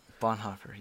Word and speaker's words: Bonhoeffer: Bonhoeffer, yes.